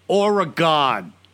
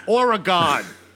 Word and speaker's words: Oregon: And I'm going to tell you how to pronounce it. Oregon. Say it. Say it with me. Oregon.